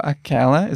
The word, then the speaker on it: aquela: And for aquela, is it open or closed? – Opened. – Could you give us pronunciation on that too? – Aquela. So pay attention. Masculine form is aquele, closed. And the feminine, aquela. It's opened.